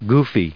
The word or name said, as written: goofy